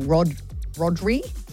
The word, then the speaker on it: Rodri: Firstly, because Barcelona has just signed Spanish midfielder Maestro Rodri, how do you say his name? – Rodri.